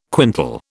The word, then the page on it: quintal: British English